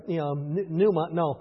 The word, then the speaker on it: pneuma: Just like PN, it's not supposed to be a sign. It's pneuma. Pneuma, right? Pronounce them all boldly.